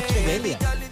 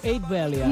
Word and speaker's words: Ocho Belial: O Eight Belial. – No, no. – És Ocho. – Segons com el pronuncien, al final de les cançons és Ocho Belial. – Ocho Belial. – Jo també pensava que seria així en anglès i tot, però no.